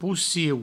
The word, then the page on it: pociu: poucieu